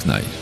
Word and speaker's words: knife: No Public House avec le morceau Twist the Knife. – The Knife. – Knife. Ah, knife. Oui. – Là, knife. – Tu prononces pas le K.